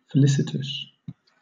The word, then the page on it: felicitous: British English